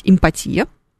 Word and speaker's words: эмпатия: Правильно говорить «эмпатия», но мне кажется, так не говорит вообще никто, потому что все всегда психологи, все специалисты, все используют слово «эмпатия», безусловно, и если вот вы ведете какую-то сессию с вашим клиентом, с вашим...